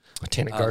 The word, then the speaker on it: Botanic Garden: Am I saying that correctly? – No. It's the West Virginia Botanic Garden. – Botanic Garden. – A lot of people do make that mistake. It's Botanic Garden without the A-L.